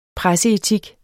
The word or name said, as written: presseetik